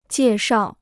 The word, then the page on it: 介绍: jiè shào